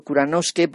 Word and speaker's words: Kuranosuke: Yo siempre he dicho Kuranosuke porque es la manera en que se pronuncia, pero se escribe Kuranosuke, que es otro de estos títulos que hemos oído profusamente en el relato, ¿no?